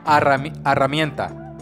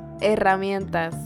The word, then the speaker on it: herramientas: Herramienta. – Herramientas.